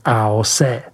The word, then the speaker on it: AOC: statut AOC, vyslovujeme AOC.